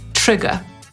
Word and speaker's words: trigger: Słowo zapisywane jako trigger, czyli przez 2G, pochodzi z języka angielskiego i wymówimy je trigger. Tak powie Brytyjczyk, Amerykanin trigger. Jeżeli na samym początku słyszycie coś, co przypomina polskie czy, to dobrze, bo właśnie tak wymawiamy angielskie słowa rozpoczynające się w pisowni na TR.